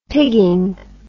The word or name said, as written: pigging